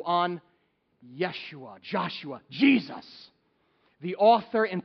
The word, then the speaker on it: Yeshua: Here's how you say it in Hebrew, Yeshua. Here's how you say it in Greek, Jesus.